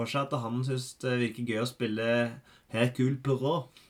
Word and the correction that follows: Poirot: Perot. Mm. – Poirot.